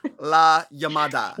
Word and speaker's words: La Llamada: La Llamada.